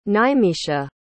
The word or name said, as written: nimeesha